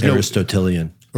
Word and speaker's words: Aristotelian: So just is that how you say it? I've never read it. I've only heard it read. – Aristotelian, yeah. Yep. Aristotelian.